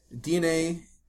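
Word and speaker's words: DeNA: Oh, yeah, so DNA... It is pronounced DNA. I thought so. Other people were calling it Dana.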